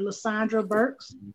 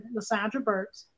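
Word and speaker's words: Lasondra Burks: You can go to books and type in Lasandra Burks. My name is spelt just the way it sounds. LASONDRABURKS. So go to Amazon Books and type in Lasandra Burks.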